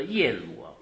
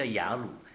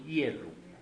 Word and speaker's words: 耶鲁: 我查了圣字典，叫做耶鲁，为什么我们都叫雅鲁？圣经很那个字典很清楚，叫做耶鲁，发音耶鲁的女儿。